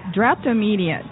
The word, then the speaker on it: drapetomania: Now, there's an exhibit I want to ask you to explain uh, drip, draptomania. Am I saying it right? – Draptomania. draptomania.